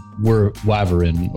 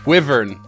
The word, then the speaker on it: Wyvern: Maybe there's another big bad classical Greek creature that we haven't touched on, like the Wavarin or the what now? – Wervin? Wavarin? The other Drake. – Wyvern.